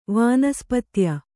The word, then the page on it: ವನಸ್ಪತ್ಯ: vanaspatya